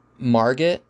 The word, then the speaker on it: Margot: I don't think it's until we get to the introduction of Margot, I believe is her name is pronounced, M-A-R-G-O-T, or Margot.